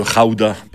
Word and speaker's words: gouda: Le pays d'où vient le fromage, le gouda ou le raouda, parce qu'ils prononcent les G comme des R dans ce pays, c'est les Pays-Bas.